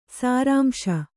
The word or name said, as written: ಸಾರಾಂಶ